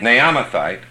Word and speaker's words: Naamathite: Then Zophar the Nahamite, Naamathite, I can't pronounce that. Naamathite, thank you.